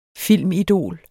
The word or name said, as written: filmidol